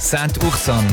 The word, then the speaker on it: Saint-Ursanne: Und im Jura ist die A16 Delémont-Boncourt zwischen Glovelier und Saint-Ursanne in beiden Richtungen wegen Bergungsarbeiten weiterhin gesperrt. – SRF 3 Wochenrundschau. Alle gegen die Patti Basler. – Hast du es richtig gesagt? Saint-Ursanne. – Ja, Ursanne.